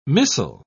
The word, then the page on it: missal: British English